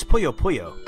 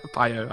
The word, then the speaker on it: Puyo Puyo: I think it's Puyo Puyo. – Puyo Puyo, I think you're right. So. Pio. I was thinking Pio. I'm hungry.